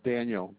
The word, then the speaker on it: Daniel: First name is Daniel, D-A-N-I-E-L.